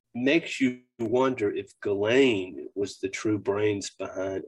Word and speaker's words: Ghislaine: the name's it's a tough name to say, and I you know I mess it up all the time, but the S is silent when you say it in this kind of French Belgium uh, posh way to say it. Ghislaine. So Ghislaine.